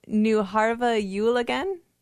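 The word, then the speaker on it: Nu har vi jul igen: She says, pronounced Nuharva Yule again, with the words translating to Christmas is here again and Christmas lasts till Easter.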